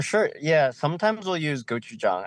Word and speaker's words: gochujang: And I'm just curious, do you have anything that's got gojing gojing on it, like a gojing th- sauce? – Oh, like like gochujang?